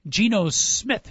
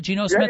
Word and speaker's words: Geno Smith: Brand new coach for West Virginia, but they got a great quarterback in Geno Smith, did I get the name right, Geno Smith? – Yeah, you got it right, Coach.